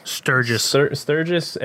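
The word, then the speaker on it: Sturgess: It stars Jim, is it Sturgis? – Sturgis.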